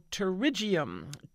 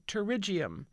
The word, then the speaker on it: pterygium: Okay, the next one is a P word, but it's pronounced like a T word. It's called pterygium. Pterygium. It's spelled P-T-E-R-Y-G-I-U-M. Pterygium.